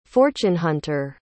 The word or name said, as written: fortune-hunter